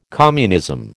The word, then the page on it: communism: American English